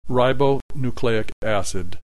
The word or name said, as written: ribonucleic acid